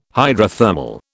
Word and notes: hydrothermal: British English

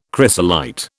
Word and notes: chrysolite: British English

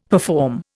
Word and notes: perform: Indian

